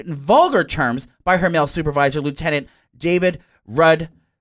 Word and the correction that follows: Rudnitsky: How do you say that? David Rudnitsky.